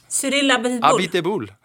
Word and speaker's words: Abiteboul: Hur uttalar du han? – Cyril Abiteboul. Abiteboul, ja. Säger jag.